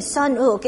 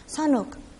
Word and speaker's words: Sun-uk: And even my name, Sun-uk, and nobody could pronounce correctly for me to respond to the calling of my name, Sun-uk. And I said, they say, son, ook, ook, and that kind of ook sound, it is garter sounds, but it's American does not have that sound like the Korean, so I could not respond to it.